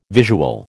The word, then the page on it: visual: American English